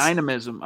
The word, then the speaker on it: dynamism: He's what the Broncos need a, a, a, a shot of explosion and youth and dynamicism. Dynam- can never pronounce that you try. Dynamicism? – I See, think it's, dynamism. I think it's dynamism. Dynamism? Okay. Yeah.